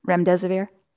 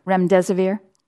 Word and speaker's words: Remdesivir: Remdesivir. – How do you say it? – Remdesivir.